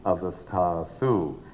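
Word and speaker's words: avasthāsu: You have to pick up that next syllable. Jagrat adishu avasthasu.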